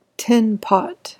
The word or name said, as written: tinpot